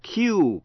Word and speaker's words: queue: Một chữ nữa là Q, Q-U-E-U-E, nghĩa là hàng người chờ.